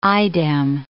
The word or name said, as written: idem